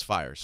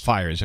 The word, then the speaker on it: Fiers: So, and by the way, is it Mike Fears or Fiers? How do you pronounce his name? – I think it's Fiers. – Fiers, okay.